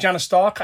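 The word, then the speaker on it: Janus Stark: I certainly know him from The English Dogs, Janice Stark, you pronounce it Janice Stark after the comic book character.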